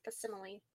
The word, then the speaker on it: facsimile: facsimiles. – Similes. Facsimile. It's saying it's facsimile. – Facsimile. I've heard it pronounced facsimile.